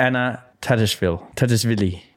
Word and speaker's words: Anna Tatishvili: In her Australian Open debut, she lost her first round match to Anna Tatishvili.